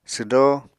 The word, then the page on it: swdó: apatani